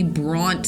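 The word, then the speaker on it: Bronte: But he does then say, right here, going towards Abilene on both sides or potentially Bront side. Because remember, that's how you pronounce the name.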